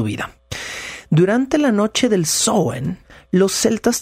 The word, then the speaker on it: Samhain: Y por oposición, el Samhain, o el, bueno, el Samhain, que es la pronunciación correcta,